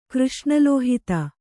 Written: ಕೃಷ್ಣಲೋಹಿತ

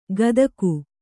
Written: ಕದಗು

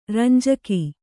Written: ರಂಜಕ

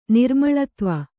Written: ನಿರ್ಮಳತ್ವ

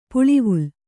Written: ಪಲವಲ

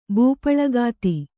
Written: ಬೂಪಳಗಾತಿ

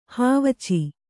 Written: ಹಾವಚೆ

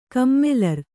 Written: ಕಮ್ಮಲರ್

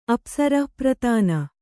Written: ಅಪ್ಸರಃಪ್ರತಾನ

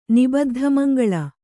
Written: ನಿಬದ್ಧಮಂಗಳ

